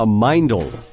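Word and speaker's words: mindle: Sam, you talk about something called a mindel. Am I pronouncing that right? – You are.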